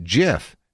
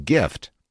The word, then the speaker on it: GIF: GIF is pronounced like the peanut butter brand Jif, not like gift.